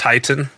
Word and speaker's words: Teton: Oh, that is the sound of a Teton Cider Works Washington State Apricot Cider. – I think that's pronounced Titan.